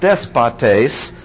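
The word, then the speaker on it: despotes (δεσπότης): The word used here is despotas, pronounced despotes, from our English despot, or from which comes our English despot.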